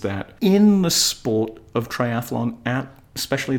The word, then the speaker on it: triathlon: It is not triathlon. Why do people insist on putting the extra vowel sound in there?